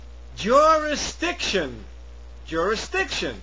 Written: jurisdiction

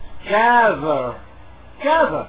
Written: gather

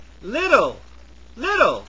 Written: little

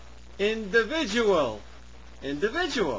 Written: individual

